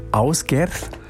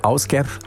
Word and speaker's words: Ásgeir: Ásgerð. Ja, spricht sich so. Ásgerð schreibt sich Ásgeir.